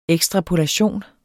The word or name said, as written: ekstrapolation